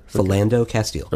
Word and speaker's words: Philando Castile: Philando Castile.